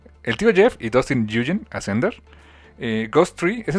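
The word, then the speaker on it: Nguyen: Se pronuncia Yugen. Dustin Yugen, ok, ya aprendí con una muy ligera N al principio, Yugen.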